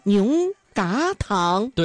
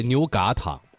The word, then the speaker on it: 牛轧糖: 哎，那个牛轧糖啊，正确的读音呢、啊，应该叫牛轧糖。 – 对，牛轧糖。